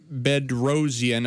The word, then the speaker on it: Bedrosian: Ty Buttry and Cam Bedrosian, if I'm pronouncing that correctly.